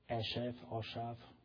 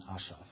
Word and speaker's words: Asaph: But when I read Psalm 73, when Asaph, how do you pronounce it? Asaph, who wrote the Psalm 73? Asaph, sorry. In Hungarian pronunciation, Asaph, so Asaph.